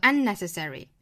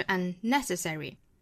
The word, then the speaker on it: unnecessary: 那就是 friendship is un 那注意这个 unnecessary 这个单词的重读呢是在第二个音节，就是在 net 这个位置上 unnecessary，不要读成了 unnecessary，应该是 unnecessary。